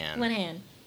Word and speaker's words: Linehan: And DBT was created by Marsha Linehan. Is that how we say her name? – Linehan.